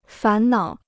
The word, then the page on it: 烦恼: fán nǎo